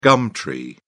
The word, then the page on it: gum tree: British English